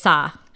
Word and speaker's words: Sa: Yeah, it's Sa.